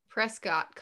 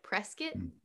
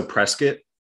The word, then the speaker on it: Prescott: and it looks like Prescott College, but it's not. It's pronounced, you said, like biscuit, so like Prescott College. – Yeah, exactly. Yeah, it's so Prescott.